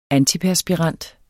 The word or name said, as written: antiperspirant